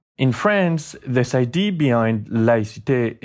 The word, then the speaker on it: laïcité: which is called laïcité. – And what is laïcité? And am I saying it correctly? Laïcité. – Laïcité, yeah, that's fine this way.